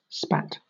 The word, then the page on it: spat: British English